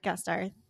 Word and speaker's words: Gasteyer: Then we have Catherine, played by Anna Gasteyer. I am probably butchering that. I've seen her in like a few Gasteyer. – Things. Gasteyer.